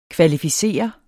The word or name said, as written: kvalificere